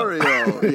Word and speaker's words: Purgatorio: Just real quick off the top, is the episode pronounced Purgatorio or like Purgatorio? Yeah. Like, it's a me, Purgatorio. – Purgatorio.